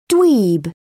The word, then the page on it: dweeb: British English